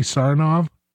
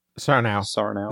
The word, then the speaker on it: Sarnow: Would that be Sarnow? – Sarnow. That's how I've always pronounced it.